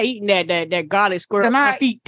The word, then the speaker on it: confit: It's confit. It's French.